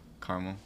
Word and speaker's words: caramel: Do you say caramel or caramel? – What's caramel. – Caramel. That's how you say it. That's how you're supposed to say it.